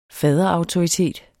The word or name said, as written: faderautoritet